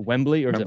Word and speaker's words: Wembley: Well, today, I'm sure you watched wrestling, but in Wembley Stadium, is that how you say it? – Wembley's fine.